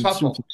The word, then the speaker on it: Suffolk: Suffolk. Is that how you pronounce it? Suffolk? Yeah, Lankenheath and Suffolk. – Suffolk, Yeah, Suffolk. Okay.